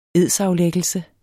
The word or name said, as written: edsaflæggelse